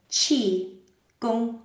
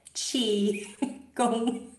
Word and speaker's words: qigong: And in Mandarin, you pronounce it qi, gong. Okay. So the few of you who are on screen, can I just see your mouths doing that? Qi, gong, like cheese.